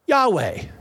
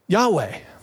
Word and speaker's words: Yahweh: We look at that and go, we don't know how to pronounce that. So we go ahead and throw some vowels in there and we go ahead in English and pronounce it Yahweh, Yahweh.